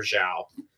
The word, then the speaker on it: Zhao: Zhao. They did get his name right, at least.